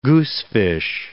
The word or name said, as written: goosefish